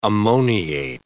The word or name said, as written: ammoniate